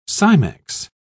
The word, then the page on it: cimex: British English